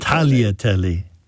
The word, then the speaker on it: tagliatelle: Well, G-L-I in Italian is always pronounced lia, so it's tagliatelle. – Tagliatelle, yeah.